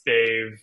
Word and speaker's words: Dev: But if phonetically looks like Dev, it is pronounced Dave. That's how they pronounce it in India. I don't knock people who have the same name as me who they themselves pronounce it Dev.